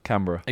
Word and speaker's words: Canberra: Canberra. – Existed. Canberra. – Yeah. – How you spell that? – C-A-N-B-E-R-R-A.